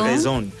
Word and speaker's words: Bresson: Los franceses no pronuncian la R. – La R es como... – Bresson.